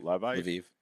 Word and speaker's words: Lviv: How do you pronounce it? – I think it's Lviv. – Lviv. Yeah.